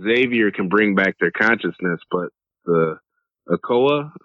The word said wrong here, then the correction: Krakoa: or I think I'm saying it wrong. – Krakoa, the island, yeah.